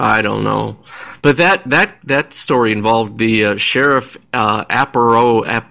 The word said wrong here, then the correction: Arpaio: how do you pronounce his name? Sheriff, the famous sheriff, the anti-immigration sheriff, in Maricopa County. What's the guy's name? Joe Arpaio, that's his name.